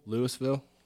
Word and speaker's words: Louisville: No, I'm pretty sure they pronounce it Louisville. – Louisville? – Yeah, I heard you. I heard you're supposed to pronounce it like you have a mouthful of spit.